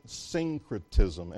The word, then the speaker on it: syncretism: You pronounce it syncretism, S-Y-N-C-R-E-T-I-S-M.